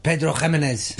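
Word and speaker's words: Pedro Ximénez: Pedro Jimenez.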